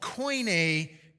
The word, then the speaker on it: Koine: The New Testament was written in what's called Koine Greek. It's spelled with a K with an E on the end, Koine.